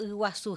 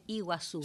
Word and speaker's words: Iguazú: Por ejemplo, Iguazú, generalmente se dice Iguazú. – Sí. – Pero no está bien dicho, es Iguazú, que es aguas grandes.